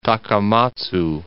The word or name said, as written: Takamatsu